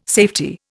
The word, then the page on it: safety: Indian